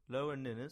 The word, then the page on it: Lower Ninnes: English